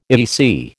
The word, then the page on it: EEC: American English